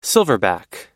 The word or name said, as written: silverback